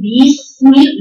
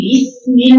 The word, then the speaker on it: بسم: لا عايزه اجيب بقى كسر باسم عايزه اكتبها لا معلش اتلغى في كسر هنا فتقول باسم لا ده تنطق كعملتها ياء المفروض انها تقول ايه بسم الله زي بسم الله الرحمن بسرعه بدون تنطع